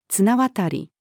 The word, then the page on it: 綱渡り: female